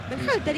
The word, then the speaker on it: Berhalter: ¿Cómo es el apellido? – ¿Cómo? – Dígalo usted. – Berhalter, ¿y qué dije yo? – No, impecable.